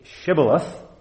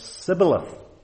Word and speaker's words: Shibboleth: Say Shibboleth, and they had a problem with their sibilance, and they said Sibboleth.